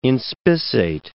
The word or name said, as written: inspissate